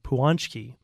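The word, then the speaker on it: pączki: I was pronouncing the Polish pastry incorrectly. It's spelled P-A-C-Z-K-I. I've been told by, let's see, Maggie near UTA to try saying it like Puanchki.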